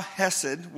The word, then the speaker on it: chesed: Chesed, not he said, she said. Chesed. It's actually spelled in Hebrew, C-H. Chesed. And if you want to say it right, you need to act like there's a piece of popcorn in the back of your throat that you're trying to get out.